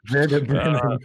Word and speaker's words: Bremen: Freiburg at home against Bremen. I can't pronounce it the way they do. – Bremen. Yeah. – It's a nice little underdog there. – Yep, like that.